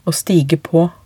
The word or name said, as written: å stige på